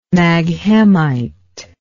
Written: maghemite

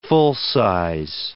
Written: full-size

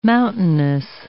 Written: mountainous

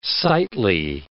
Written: sightly

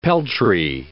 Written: peltry

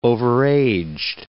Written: overaged

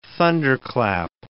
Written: thunderclap

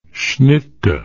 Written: schnittke

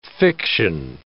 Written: fiction

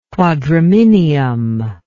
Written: quadrominium

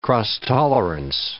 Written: cross-tolerance

